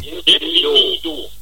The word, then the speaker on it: Bienvenido: Ich würde jetzt spielen wollen, wenn ich das richtig ausspreche, Bienvenido, oder wie? – Bienvenido.